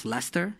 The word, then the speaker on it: Leicester: So you're from a small town, it's called pronounced Leicester? – Yes.